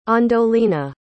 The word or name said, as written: andolina